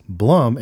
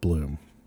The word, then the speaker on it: Blum: Faith won the argument because she was correct that it's Blum and not Bloom.